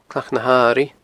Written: Clach na h-Airighe

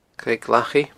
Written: Creag Lachaidh

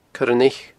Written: coiraneich

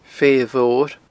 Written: Feithe Mhor

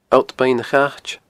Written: Allt Beinn a Chait

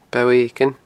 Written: Bo Eiginn

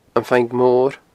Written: Am Faing Mor

